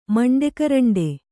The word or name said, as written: ಮಂಡೆಕರಂಡೆ